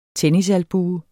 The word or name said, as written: tennisalbue